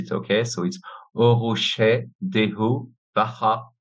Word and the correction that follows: Óró sé do bheatha abhaile: Close enough. It's Óró sé do bheatha abhaile by Sinead O'Connor.